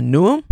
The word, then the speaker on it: Newham: I believe it's pronounced Newham.